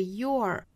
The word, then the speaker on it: you're: 那美音版我们来看呢就是 Sometimes that thing you're searching for, 这个 your. 那同样呢这个如果缩约在一起呢 you are 要读成 your, 因为后面有一个字母 r, 所以需要卷舌成 r, your, 跟那个 your 的美式发法也是一样的也是 your, 那在弱读是 your.